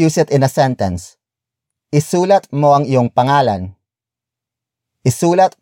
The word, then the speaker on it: isulat: For example, isulat, isulat, isulat. Isulat is spelled as I-S-U-L-A-T.